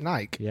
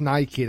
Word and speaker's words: Nike: Now, I know it's spelt like Nike, as in bike or Mike or whatever, but genuinely, it's named after the Greek goddess of victory, which was, I think, Nike, and it is called Nike.